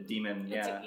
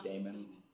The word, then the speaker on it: daemon: Like a demon, or it's spelled like Daemon, but so I keep wanting to say Daemon. But that's just how my brain says it.